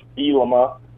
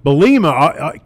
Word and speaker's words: Bielema: I love Coach Bielema, now that I know how to say it.